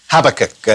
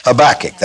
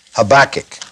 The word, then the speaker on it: Habakkuk: Habakkuk, or Habakkuk, as some would say. What do the silver-tongued orators call him? Habakkuk. That's correct. The accent is on the second syllable, Habakkuk.